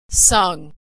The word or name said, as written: sung